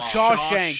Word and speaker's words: Shawshank: It was Shangsha Redemption. – Oh, Shawshank. Shawshank.